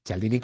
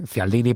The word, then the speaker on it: Cialdini: Cialdini con la pronunciación italiana, ¿eh? Cialdini para el resto del mundo.